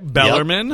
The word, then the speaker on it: Bellarmine: Bellarmine.